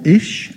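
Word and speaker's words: ish (אִישׁ): The Hebrew word used for man here is is, I-S, or ish. It's pronounced ish, I-S.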